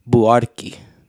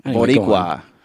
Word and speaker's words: Buarque: And his name is Chico Buarque. I was saying it differently. – Boricua. – Was that what I was saying? Boricua.